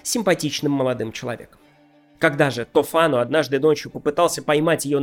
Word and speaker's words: Тофано: Итальянцы произносят это имя как Тофано, но вы уж простите, я сохраню то произношение, к которому приучил меня Ерорт Масс, иначе говоря, третий участок, где мы обсуждали когда-то Декамерон с дядей Араиком. Так вот, сказанный Тофано ни с того ни с сего принялся ревновать свою жену, красавицу Монну Гиту.